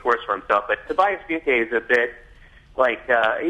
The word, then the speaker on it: Funke: I mean, I'm thinking both of your character Tobias Funk am I saying that right? Funke, that is, in Arrested Development. – It is Funke, yeah.